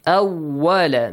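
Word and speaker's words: Awwala (أول): Awwala, make sure waw is not pronounced as v in English.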